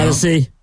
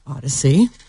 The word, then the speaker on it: Audacy: Odyssey. – Yes, Odyssey. If you don't have the app, it's free. It's spelled A-U-D-A-C-Y, like think audio. Audacious. Odyssey.